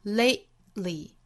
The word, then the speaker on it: lately: 在 lay 和 li 这两个音节之间，声音顿开了，憋掉了，用嗓子去憋一下这个音，lately。 Lately，那我们在发音的时候呢，一定要注意不能把这个音完全给省略掉了，而是要有这样的一个顿促的时间延长。